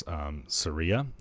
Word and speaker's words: Ceria: So it's Ceria, I think it's pronounced, C-E-R-I-A.